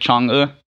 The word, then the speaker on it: Chang'e: I looked up the pronunciation of that last night. So it's like Chang'e.